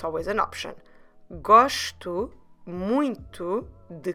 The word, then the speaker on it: muito: M-U-I-T-O. And I know, and we've talked about this before in, on other episodes, but the word muito, it is quite nasal in the beginning, although this time, for once, the spelling doesn't let us know about it. So just remember to use a bit your nose as well in the beginning of the word for the first syllable and try to pronounce it a bit nasal. Muy, muy, muito, muito.